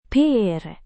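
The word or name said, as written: pramugari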